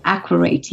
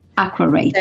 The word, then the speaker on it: Aquarate: And is HydroCup connected in this way with Adequate, or how do I pronounce this? – Aquarate, yes, Aquarate. – So aqua, like water, and accurate water.